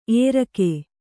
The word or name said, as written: ಎರ್ಕೆ